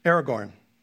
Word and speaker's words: Aragorn: Aragorn.